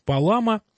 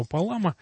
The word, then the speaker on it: Палама: Итак, святитель Григорий Палама, есть еще ударение Палама ставят, да, есть, можно и так, ну, кто как. Во всяком случае, мы, я придерживаюсь вот этого Палама, ну, так на русский манер скажем.